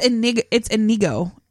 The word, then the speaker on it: Inigo: It's Inigo. – Oh. – I-N-I-G-O.